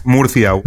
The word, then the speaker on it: Murcia: Murcia? How do you say this one? Murcia? – Murcia. – Murcia. – Yeah. – Oh, that's right, because so you pronounce your C's like a th sound.